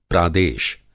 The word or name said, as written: प्रादेश